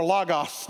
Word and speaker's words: logos: They had this Greek word called logos or logos, depending on who taught you Greek. How do you say it? – Logos. – Logos. See, I learned from Harvey Floyd that it's logos. I'm just saying, it can go either way.